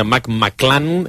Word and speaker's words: McClung: McClung, s'escriu, eh? – McClung, McClung, sí. Però es pronuncia McClan, és aquest... – Com el grup, McClan. – Sí, és una mica això, McClan.